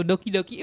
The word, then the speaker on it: Doki: Look, it rhymes with Loki, right? It looks like Loki, but with a D instead of an L. – I'm up all night to get Loki. – Anyway, so Doki Doki.